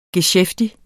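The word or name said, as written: GESKÆFTIG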